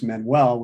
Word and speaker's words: Manuel: You know, Dai is a Welsh name for David, and it's spelled D-A-I. And my last name is Manuel, which is Portuguese, M-A-N-U-E-L.